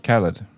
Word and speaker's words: Khalid: And I haven't been really digging her music that much lately, but she has a song with Khalid that, how do you say his name? Is it Khalid? It's Khalid, right? – Khalid.